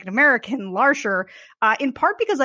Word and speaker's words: Larcher: I do know enough French pronunciation to know that L-A-R-C-H-E-R is pronounced Larcher, not Larcher.